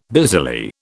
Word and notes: busily: British English

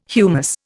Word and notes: humus: Indian

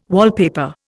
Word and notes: wallpaper: Indian

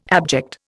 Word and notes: abject: Indian

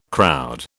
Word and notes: crowd: British English

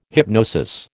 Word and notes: hypnosis: American English

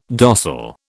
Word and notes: dossal: British English

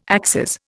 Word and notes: axes: Indian

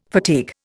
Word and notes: fatigue: Indian

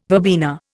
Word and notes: verbena: Indian

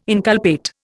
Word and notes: inculpate: Indian

